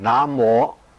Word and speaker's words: 南无: 南无读成南无，这两个字在佛门用得很普遍，用得最多。南无就是恭敬的意思，礼敬的意思，亦有皈依的意思，即是皈命。咁在梵语里面称为那摩，当时就翻成南无这两个音。